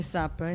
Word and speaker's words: PSAP: Si chiamano SAP, la pronuncia è SAP, S-A-P, in realtà si scrive PSAP, cioè P-S-A-P.